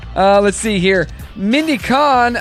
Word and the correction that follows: Cohn: how do you say her name? Cone or Kahn? C-O-H-N. – That was Cone.